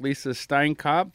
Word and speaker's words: Lisa Steinkopf: We're back with Lisa Steinkopf.